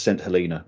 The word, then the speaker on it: Saint Helena: It is pronounced St. Helena, by the way, if you're on the island, that's how they say it. So I've adopted that pronunciation.